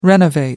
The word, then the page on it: renovate: American English